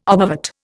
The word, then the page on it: obovate: Indian